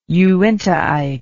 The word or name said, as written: uintaite